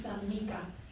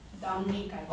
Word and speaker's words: Dominica: My name is Dominica. – Amica. – Dominica. – Oh, Dominica. – D-O-M-N-I-C-H.